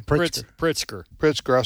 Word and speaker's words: Pritzker: Pritzker.